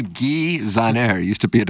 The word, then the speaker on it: Zahner: That's from Guy, do you pronounce it Zahner? Z-A-H-N-E-R? – I think that's Zahner, yeah.